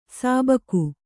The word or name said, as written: ಸಿಬಿಕೆ